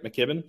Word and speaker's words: McKibbin: Did I say that right, McKibbin? – You did, yeah.